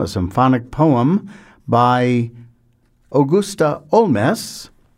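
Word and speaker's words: Holmès: She was born Augusta Holmes, of Irish parents, but when she became a French citizen officially, she added an accent grave to her last name and started pronouncing it as if it were French.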